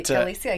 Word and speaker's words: Galicia: Chelsea, I hope your last name is pronounced Galicia, but... – Galicia, yeah.